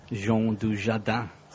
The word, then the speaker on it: Jean Dujardin: Jean Dujardin.